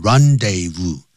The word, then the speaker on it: rendezvous: The Moulin Rouge was the rendezvous, 就念成 rendezvous, du high life.